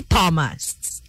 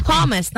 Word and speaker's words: Thomas: It's really Thomas. The H there is silent.